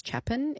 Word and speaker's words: Chapin: Tony also says that my song about my family is Harry Chapin. It's Chapin. It doesn't sound like I'm saying that right.